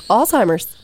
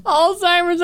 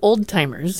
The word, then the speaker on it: Alzheimer's: Say that one more time. – Alzheimer's. I've been saying it wrong my whole life. – Yeah. – Well, when I was a kid, I called it old-timers. – Yeah, most people, most little kids do. – It's Alzheimer's.